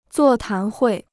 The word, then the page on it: 座谈会: zuò tán huì